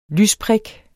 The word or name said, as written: lysprik